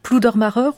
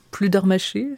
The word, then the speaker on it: Pludermacher: Le vent dans la plaine, l'un des préludes du premier livre de Claude Debussy, Georges Pludermacher ou Pludermacher, suivant les prononciations, dont les parents étaient présents à la maison d'Izieux en tant qu'éducateurs.